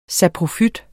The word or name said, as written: saprofyt